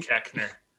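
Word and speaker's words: Koechner: Pronounced Keckner.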